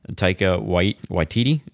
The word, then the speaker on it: Taika Waititi: Taika Waititi. Is that how you say it? – Yes, that is how you say it.